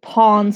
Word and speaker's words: pawn: Can I specify for people who don't share your specific accent that you did say pawn stars and not porn stars, which is exactly how you pronounce that.